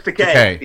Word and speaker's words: Takei: George Takei. – It rhymes with gay.